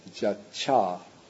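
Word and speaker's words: jacca: The T-Y turns into a double C. And so what results is ja-cha.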